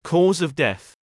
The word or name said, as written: cause of death